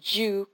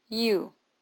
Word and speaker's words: you: Acuérdense que no es you como la Y en español. Se pronuncia como una IU. Como lo pueden ver en el libro, en la página 32 del librito, se pronuncia IU.